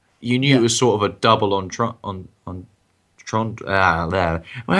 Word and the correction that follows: double entendre: how do you say that word? – Double on entendre. but double entendre. – Double entendre. There we go.